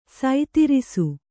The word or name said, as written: ಸೈತಿರಿಸು